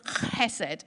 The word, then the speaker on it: hesed: There's a kind of sound at the beginning that I can't do, but it's kind of hesed, okay? But it, it's when you transliterate it, it begins with an H.